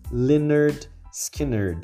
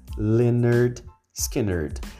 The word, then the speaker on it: Lynyrd Skynyrd: da banda que tem um nome esquisito, Leonard Skinner, cheio de Ys, mas a pronúncia é simples, assim como a música, Simple Man, Leonard Skinner.